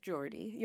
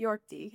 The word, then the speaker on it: Jordi: Yeah, it's my maiden name is Kachadorian, and my married name is Jordi. Jordi. – Jordi, huh? Yeah. My husband's Swiss, so the New York different pronunciations and stuff like that.